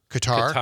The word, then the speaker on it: Qatar: Speaking of the Milwaukee Film Festival, there's one called 25 Cats from Qatar. Am I saying that right? – That was here too, yeah. – Qatar.